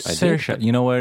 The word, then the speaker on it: Saoirse: I keep forgetting how we pronounce her name. Shara Ronan. Guys, Saoirse.